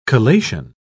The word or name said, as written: collation